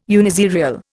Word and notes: uniserial: Indian

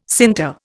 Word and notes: sinter: Indian

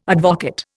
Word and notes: advocaat: Indian

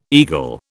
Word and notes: eagle: British English

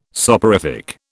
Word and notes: soporific: British English

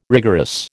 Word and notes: rigorous: American English

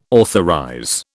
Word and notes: authorize: British English

